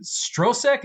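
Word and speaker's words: Stroszek: is I think Strosek. Is that how it's pronounced? – Yes.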